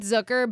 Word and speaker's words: Zucker: It's the same thing with Zucker, but it's the way that it's pronounced is like Hooker with a Z.